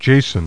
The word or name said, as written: JASON